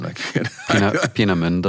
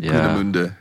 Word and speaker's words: Peenemünde: Peenemünde. Again, I can't pronounce German. – Peenemünde. – Peenemünde.